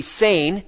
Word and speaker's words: seine: Not very often, but it's pronounced that word right there, S-E-I-N-E, seine, seine fishing, and it comes from the Greek word sagini, which means dragnet.